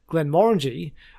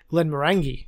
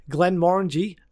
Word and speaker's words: Glenmorangie: I think the best way to remember how to pronounce it is Glenmorangie doesn't taste orangey.